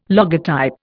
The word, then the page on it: logotype: Indian